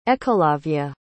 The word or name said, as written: Ekalavya